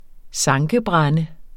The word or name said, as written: sankebrænde